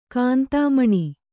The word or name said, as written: ಕಾಂತಾಮಣಿ